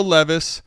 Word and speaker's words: Levis: So you get his name right. Will Levis, I think, is what makes this deal for Mike a no.